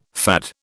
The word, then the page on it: FAD: British English